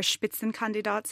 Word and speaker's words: Spitzenkandidat: Well, one thing that's worth noting is that the entire Spitzenkandidat system, so the idea that... – That's how you say it. – Spitzenkandidat.